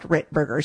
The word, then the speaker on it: Ritberger: Always confuses people because I pronounce my name Ritberger, which it is, but it's spelled Ritberger.